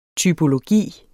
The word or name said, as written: typologi